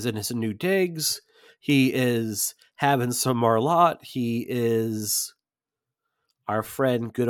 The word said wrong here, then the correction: Malort: It is pronounced Malort.